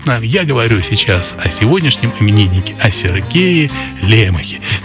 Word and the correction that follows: Лемох: Сергей Лемох, как правильно говорить,